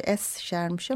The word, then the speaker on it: Escher: Okunuşu Escher'miş ama onu tam e, bazen telaffuz edemeyebilirim, kaçırabilirim Escher diye.